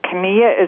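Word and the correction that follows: Chania: Is that where I should be looking at Hania? You mean Hania? – Yeah. – Hania.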